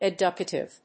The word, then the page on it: educative: ed・u・ca・tive